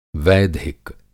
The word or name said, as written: वैधिक